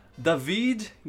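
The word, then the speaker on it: Daveed: É, Jonah Howard King, não conheço ele, Príncipe Eric e Dave, David Giggs. Eu acho que é assim que se pronuncia, porque é, é D-A-V-E-E-D.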